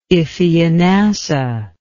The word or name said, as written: Iphianassa